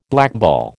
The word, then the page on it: blackball: American English